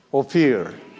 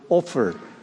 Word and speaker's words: Ophir: Christ guarantees that he will make a man more precious than fine gold, even a man than the golden wedge of Ophir. Some people use the word Ophir, but I checked it out. The real correct pronunciation is, which I learned this morning, Ophir.